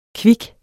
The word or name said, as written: kvik